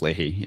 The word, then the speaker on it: Leahy: Senators Thom Tillis, a Republican, and um, Patrick Leahy, if, if that's how you pronounce that, a Democrat. – Yeah, Patrick Leahy.